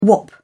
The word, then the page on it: wop: British English